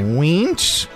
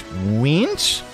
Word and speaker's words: Wiench: Wientz. – Yeah. – W-I-E-N-C-H. Wientz.